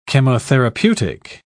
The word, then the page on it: chemotherapeutic: British English